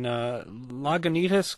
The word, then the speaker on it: Lagunitas: This time, let's go to Jennifer in Lagunitas, California. Did I say that right, Jennifer? – Yes, you did.